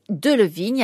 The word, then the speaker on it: Delevingne: Et donc, Delevingne, avec cette prononciation française, et croyez-moi, elle le revendique parce que la France, c'est l'aristocratie.